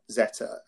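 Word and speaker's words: Zetter: Well, all they do, they just go into YouTube and they search for me, not me, not M-E, but Neil Zeta.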